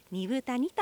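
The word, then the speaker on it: 二風谷: で 2 時間ほど、ラジオ講座を支えてくださっている関根健二さんが住んでいるアイヌの里、ビラトリ町二部谷にやってきました。豊かな自然と緑の森に囲まれ、猿川が流れる中、アイヌの伝統が色濃く残る地域です。二つの風の谷と書いて二部谷と読みます、はい。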